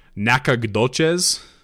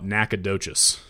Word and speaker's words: Nacogdoches: I'm going to try and pronounce this phonetically, Nacogdoches. Although nowadays Texans just call it Nacogdoches.